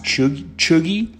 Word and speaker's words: cheugy: And the term chuggy, first of all, is spelled C-H-E-U-G-Y, but it's pronounced chuggy.